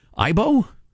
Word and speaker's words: AIBO: How do you pronounce it? Ibo.